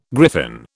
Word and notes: griffin: British English

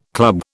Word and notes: club: British English

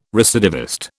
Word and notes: recidivist: British English

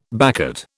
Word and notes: baccate: British English

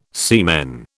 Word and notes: semen: British English

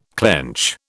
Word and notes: clench: British English

